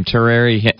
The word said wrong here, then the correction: Taraji: Henson? – Taraji Henson.